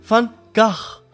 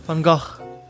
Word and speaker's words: van Gogh: Jetzt sprich mal den Namen richtig aus. Du hast eben, bevor wir aufgenommen haben, was Vincent, gesagt mit no ja, niederländischer Aussprache. Vincent van Gach. – Gach. – Aber nicht das harte deutsche, sondern das weiche.